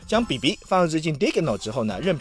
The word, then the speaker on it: Dieghino: 它的名字呢叫 Digno 啊，拼一下是 D I E G H I N O Digno。